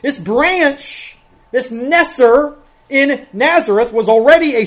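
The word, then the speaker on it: neser (נֵצֶר): In Hebrew, the word for branch is neser. The first syllable of Nazareth.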